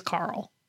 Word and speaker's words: Carel: His name in real life is Carl. – Okay. – Remember that girl named Carl? – Yes. – But it's spelled C-A-R-E-L, whereas the girl named Carl was C-A-R-Y-L.